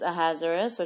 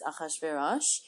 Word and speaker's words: Ahasuerus: such as Ahasuerus, which you might hear me pronounce his name as Ahasuerus, Mordecai, which I might pronounce Mordechai, and Haman.